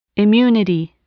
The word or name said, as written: immunity